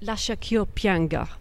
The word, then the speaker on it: Lascia ch'io pianga: Lascia ch'io pianga from Rinaldo.